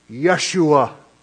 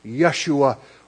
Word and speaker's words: Yeshua: The name rather was something like this, Yeshua, Yah, instead of J, Yeshua, or Yahushua, or Yehoshua.